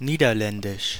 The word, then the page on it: Dutch: /dʌtʃ/